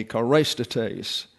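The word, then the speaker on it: chrestotes: Kindness, charistates, and it's actually a long E, charistates, gentleness toward others, regardless of who they are.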